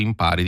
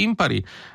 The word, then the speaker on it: impari: Si sposta l'accento, non dici impari, dici impari. Però anche qui non coniughi, non declini, diciamo così.